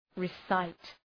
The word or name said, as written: recite